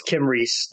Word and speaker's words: Kymrese: Wait, K-Y-M-R-E-S-E. – I think it's Kim Reese.